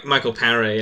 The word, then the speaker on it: Michael Paré: Yeah, Michael Paré.